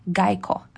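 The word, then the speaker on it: гейко: Вообще в Киото правильно говорить гайко, а не э, гейша.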